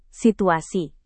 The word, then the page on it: situasi: Indonesian